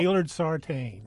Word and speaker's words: Gaylord Sartain: E-A-U-X. – Now, how do you pronounce his real name, the actor? – Well, Gaylord Sartain. – Thank you. – Gaylord Sartain.